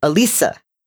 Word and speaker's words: Alissa: I found out a couple years ago that I've been pronouncing my middle name, quote unquote, wrong for my whole life. It's spelled A-L-I-S-S-A, and I've always pronounced it Alisa.